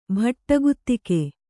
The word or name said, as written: ಭಟಗುತಿಗೆ